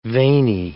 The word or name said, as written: veiny